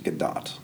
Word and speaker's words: Gadot: I've been told that it's pronounced Gadot. – Gadot? – Yes. – Okay. – Which seems weird, because everybody's waiting for Gadot. – Yeah. – Evidently, it's Gadot.